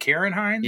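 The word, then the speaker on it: Ciarán Hinds: Is Ciaran Hines? How do you say that? Karen Hines? – Yes.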